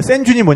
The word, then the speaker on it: sand dune: 그래서 그 보드를 들고 이제 거기서 이제 센 듄이라 그러는데 샌준이 뭐냐면 모래 언덕의 사구. 아, 샌드 듄. 네. 네. – 샌드 듄인데 네. – 그냥 붙여서 발음하면 샌준, 샌준 그럽니다.